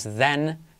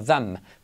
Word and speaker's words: them: En them no es then, no es then, sino them. Prestad atención a eso. Cuando veáis una M al final de palabra hay que pronunciarla.